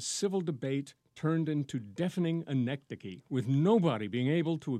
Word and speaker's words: anecdoche: See, now, I learned just now how to pronounce the word, because when I looked at it, I thought immediately anecdoche, because it looks like anecdote.